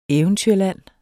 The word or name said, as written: eventyrland